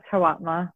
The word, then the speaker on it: Hawatmeh: It's Hawatmeh.